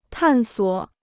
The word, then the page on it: 探索: tàn suǒ